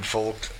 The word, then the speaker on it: Volk: Well, you know, if you speak German, you pronounce the V with an F, so you pronounce Folk. That's like Volkswagen is Volkswagen.